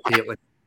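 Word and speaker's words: Hateley: Yeah, Mark Hatley.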